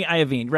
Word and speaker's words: Iovine: Jimmy Iovine, right.